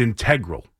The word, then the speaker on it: integral: And I looked it up, and it's integral. I believe that's the pronunciation for it.